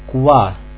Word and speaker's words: Qua: Кстати, буква «U» после буквы «Q» произносится как английская «W». Сядьте перед зеркалом. Вытяните губы в трубочку и скажите «В». Итак, «Qua» – «там».